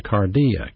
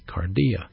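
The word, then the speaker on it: cardia (καρδία): And so in the soul resides the mind, M-I-N-D, and the heart, H-E-A-R-T. One being pronounced in the Greek New Testament, the nous, N-O-U-S, the mind, and the other being pronounced the cardia, K-A-R-D-I-A, cardia, or the heart.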